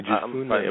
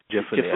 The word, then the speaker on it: Jephunneh: Two of the men had explored the land, Joshua son of Nun and Caleb son of how do you pronounce that, Oshel? Jephunneh? – Yeah, Jephunneh. I believe it's, Jephunneh. – Jephunneh, I believe that's pronounced.